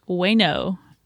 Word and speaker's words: Ueno: I am also a art director at Ueno. It is pronounced bueno. – like bueno, but without the B.